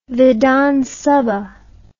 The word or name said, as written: vidhan sabha